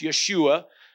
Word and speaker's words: Yeshua: Joshua himself, that actually the word Joshua is pronounced Yeshua in Hebrew.